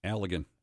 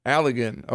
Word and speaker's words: Allegan: Allegan. – Allegan.